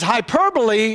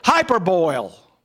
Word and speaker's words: hyperbole: So you would look at this and you'd say, hyperboil. No, that's not the way it's pronounced.